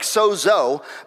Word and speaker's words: sozo: Sozo, it looks like sozo, but it's pronounced sod with a D, sozo, and it means to heal, to preserve, to rescue, to deliver, and to make whole.